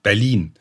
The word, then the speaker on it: Berlin: Und Berlin, wenn du das achtest, betonen wir hinten.